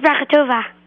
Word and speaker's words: Brachatova: Um, B-R-A-C-H-A-T-O-V-A. – B-R-A, Bracha, Bracha like that? – Yeah, Brachatova.